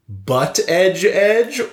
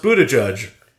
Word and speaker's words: Buttigieg: He says Buttigieg. But historically, you can either say butt edge edge or boot edge edge.